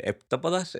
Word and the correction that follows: heptápodos: eh, los bichos. Heptápodos, de momento son los bichos.